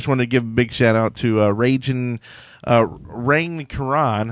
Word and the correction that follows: Raging Cajun: I'm going to try to say it right. Raging Cajun. – Raging Cajun.